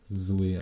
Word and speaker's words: Zoé: Weil Zoe in französischer Herkunft hat. Und im Französischen wird es äh, auch Zoé ausgesprochen.